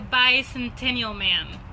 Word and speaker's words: Bicentennial Man: The Bicentennial Man. And every time I say that, I have to think about it because I feel like I'm going to say it wrong. – No, you got it.